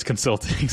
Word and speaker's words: consulting: About that guy who was like, he charges $500 an hour for his consulting service. Consulting service. I put the emphasis on the wrong syllable there. on his consulting service.